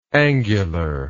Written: angularness

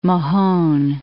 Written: Mahone Bay

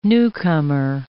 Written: newcomer

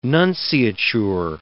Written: nunciature

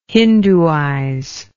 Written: hinduize

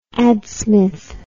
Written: adsmith

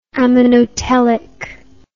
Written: ammonotelic

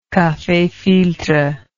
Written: cafe filtre

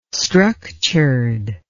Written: structured

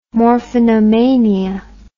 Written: morphinomania

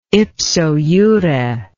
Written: ipso jure

